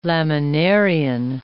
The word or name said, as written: laminarian